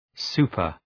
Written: super-

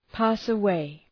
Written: pass away

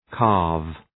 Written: calve

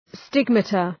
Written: stigmata

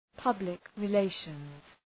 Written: public relations

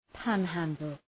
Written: panhandle

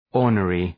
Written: ornery

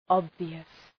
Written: obvious

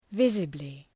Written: visibly

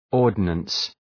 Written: ordnance

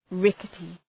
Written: rickety

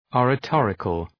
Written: oratorical

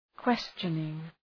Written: questioning

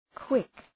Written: quick

